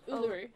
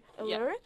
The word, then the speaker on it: Uluru: one of the areas that he went to apologies if i pronounced this wrong Uluru? Is that how you pronounce U- it? Which one? Uluru. Uluru. Yeah.